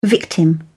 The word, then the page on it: victim: British English